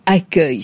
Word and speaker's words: accueil: Alors, accueil se finit en U-E-I-L. Ce qui, normalement, on est d'accord, monsieur le ministre devrait se prononcer UEI. C'est vrai que c'est très bizarre et que c'est contre-intuitif.